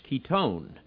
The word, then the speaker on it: chiton: It is also, the Greek word for it is C-H-I-T-O-N, and it's actually pronounced ketone, and it's the inner garment.